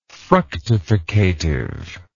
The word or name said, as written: fructificative